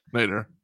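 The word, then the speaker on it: nadir: I don't know the N-A-D-I-R. I don't know the pronunciation of that Nader. – Word. – Nader. – Nader.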